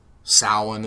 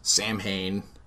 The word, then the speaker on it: Samhain: I hate that Donald Pleasance mispronounces Samhain as Samhain, but he just he mispronounces it? – Because it's known that it's Samhain. – Is it? – Not Samhain. You don't say it phonetically.